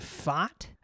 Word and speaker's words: Pfaadt: Brandon Pfaadt, I think I'm pronouncing that right.